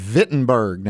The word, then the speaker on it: Wittenberg: And he has 95, I would say, theses, 95 objections, and he goes and he hammers them to the church door at Wittenberg. Now, first time I saw that, I didn't know the W, the way we pronounce it, gets the V sound, but in Wittenberg, and mm-hmm.